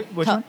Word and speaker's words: TOEFL: Am I yep. pronounced right? Yeah. – TOEFL. – TOEFL. TOEFL.